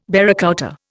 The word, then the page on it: barracouta: Indian